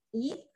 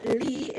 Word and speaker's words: Lee: But my book recommendation is The Evening Hero by Marie Myung Oh Yee, or Lee, if you want to pronounce it the Americanized way.